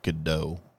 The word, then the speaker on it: Gadot: I believe it's pronounced Gadot.